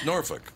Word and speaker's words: Norfolk: It is pronounced Norfolk. That's just how it's pronounced. It's F-O-L-K,